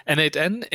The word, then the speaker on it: n8n: aber ähm, mein Gefühl war, dass äh, N8N Mhm. Wird das so ausgesprochen eigentlich? – Ja. – Okay. N8N eher ein Low-Code-Tool ist, wo man auch zwischendurch mal skripten kann.